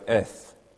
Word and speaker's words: eth: is the word eth. If you want to write it out in English, you could write A-Y-T-H, or if you pronounced it a little bit more correctly, just E-T-H would do.